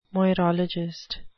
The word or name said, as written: moirologist